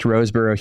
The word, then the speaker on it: Rosebrough: So I have placed Chris Roseborough's, how did I say it? The more correct Roseborough Hebrew version, I've placed that next to the ESV, okay?